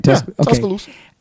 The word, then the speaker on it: Tuscaloosa: Tuscaloosa.